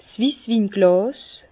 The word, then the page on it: 's Wisswinglàs: Bas-Rhin; Herrlisheim